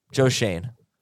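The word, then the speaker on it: Joe Schoen: Remember John Mara at the GM, I'm going to mispronounce the name, Jim Shine? – Shane. Joe Shane.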